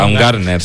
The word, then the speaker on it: Baumgartner: Baumander, o como se pronuncia. – Baumgartner, sí.